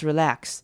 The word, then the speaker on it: relax: 好，看到今天这个句子，第一个重点呢是 just，这边 just 呢我们会把 t 省略， just relax 不会念 just relax，因为这样就把两个字念得比较慢一点了，那为了讲究速度，所以就念成 just relax。好，看到 relax 这边的 e 你可以念成 relax，长母音 e 的声音，但是这边念比较快的时候会把它变成轻音 relax.